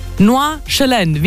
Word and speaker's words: Nordjylland: Nordjylland. – Wie als ob man Herdöpfli im Mund hat, hat sie gesagt, und den SD nicht aussprechen.